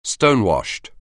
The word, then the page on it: stonewashed: anglais